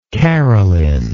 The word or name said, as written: carolyn